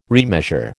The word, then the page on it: remeasure: American English